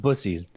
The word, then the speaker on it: Buc-ee's: I, I think it's pronounced bussies.